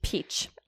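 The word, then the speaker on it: Piëch: So there's this guy who came in, Ferdinand Piech. I don't know if I said that right. – Peach. – Piech? – Peach.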